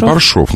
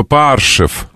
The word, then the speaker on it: Паршов: Паршов написано. – Ну ладно. – Ну почему? Ну написано Паршов. – Ладно. – Ну там О. Паршов, значит, ударение туда падает. Не может не падать. Тогда был бы Паршев.